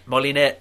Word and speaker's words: Moliner: The Emperor Augustus, Marco Polo, and Maria Molina. – Moliner. – There you go. How do you spell it? – M-O-L-I-N-E-R.